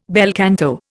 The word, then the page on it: bel canto: Indian